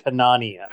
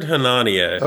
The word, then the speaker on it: Hanania: Someone said it's like Melania, so it is Richard Hanania. – Hanania? – I heard Hanania.